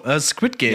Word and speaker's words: Squid Game: Squid Game.